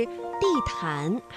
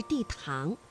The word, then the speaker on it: 地坛: 那么在平时朗读的过程当中啊，你的发音是地坛还是地堂？这个安呢是前鼻韵母，气流呢是从鼻子前方出来的。